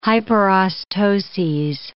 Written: hyperostoses

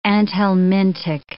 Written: anthelmintic

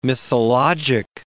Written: mythologic

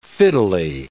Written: fiddly

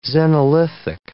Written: xenolithic

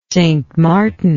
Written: St Martin

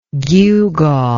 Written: gewgawed

